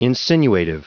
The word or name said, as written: insinuative